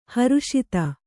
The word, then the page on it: ಹರುಷಿತ: Kannada